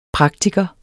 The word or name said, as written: praktiker